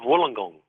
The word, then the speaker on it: Wollongong: Of Wollongong.